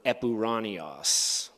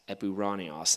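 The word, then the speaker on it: epouraniois: Now, my plan is to just give an overview of each of these times that Paul employs this word epuranios, which I translated as heavenly realms. I'll spell that for you. It's E-P-O-U-R-A-N-I-O-I-S, epuranios in the transliteration, which I've translated as heavenly realms.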